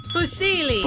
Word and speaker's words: fusilli: Hi, is it Fusilli? Is that how you say it? – Fusilli. – Fusilli Jerry. Yeah. – Fusilli. – Right. Well done.